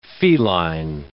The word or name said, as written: feline